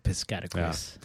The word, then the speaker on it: Piscataquis: I'm trying really hard to remember how to pronounce it. – I do believe you said Piscataquis. Yeah. Yes.